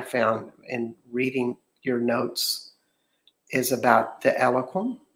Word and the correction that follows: Elohim: Is that, am I saying that right? – Yeah. – Elohim. Elohim.